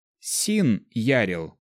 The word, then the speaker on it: Син Ярил: Мое имя, если его произносить по правилам произношения на лингва, должно звучать как Син Ярил.